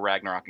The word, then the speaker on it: Ragnarok: But uh, the director Taika Waititi, who who directed Thor Ragnarok, and God, I hope I'm pronouncing that right. Yeah, um, you're good. Because Ragnarok is a hard word to say.